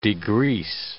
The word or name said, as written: degrease